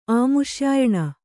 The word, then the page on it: ಅಮುಷ್ಯಾಯಣ: amuṣyāyaṇa